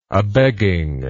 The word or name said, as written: abegging